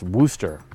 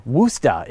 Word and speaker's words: Worcester: That word, Worcester, it's unfortunately pronounced Wooster, emphasis on the woo.